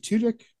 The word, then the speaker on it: Tudyk: And um, Alan is it is it Tudyk? Is that how you pronounce it? Yeah, yeah, I believe so.